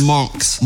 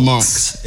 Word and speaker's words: Monks: It's Monks. – Monks. – Monks. It's M-O-N-K-S. That's how you say it.